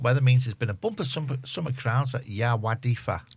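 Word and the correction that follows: Yr Wyddfa: What's that how you pronounce it? – Erwidfa. – Thank you.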